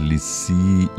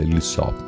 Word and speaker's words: Lisieux: Teresa de Lisieux, ¿cómo se pronunciará esto en francés? Lisieux, dice Lisieux.